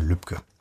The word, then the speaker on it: Lübcke: Das ist nicht Herr Lübecke, sondern Herr Lübcke.